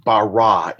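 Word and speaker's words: bara (ברא): The technical form of the verb would be pronounced bara.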